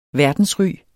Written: verdensry

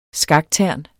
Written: skaktern

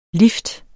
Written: lift

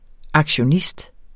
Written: aktionist

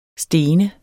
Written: stenet

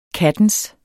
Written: kattens

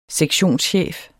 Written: sektionschef

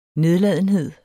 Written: nedladenhed

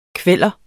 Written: kveller

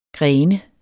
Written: gren

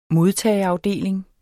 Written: modtageafdeling

